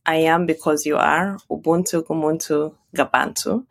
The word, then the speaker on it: Ubuntu: Ubuntu. Is that how you pronounce it? – Yes, that's how you say Ubuntu.